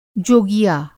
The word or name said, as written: जोगिया